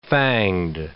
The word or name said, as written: fanged